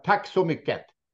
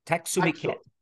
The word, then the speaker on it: Tack så mycket: How do you say thank you in Swedish? Is it close? Thank you? – Tack så mycket. – Oh, tack så mycket. – Tack så mycket. Thank you very much.